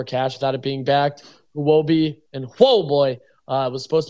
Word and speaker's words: Huobi: It's also a really interesting timing, considering there's a lot of rumors right now of Huobi being insolvent, one of the more popular Eastern exchanges. And I think it's pronounced whoa boy. – Yeah, whoa boy, also potentially being in danger.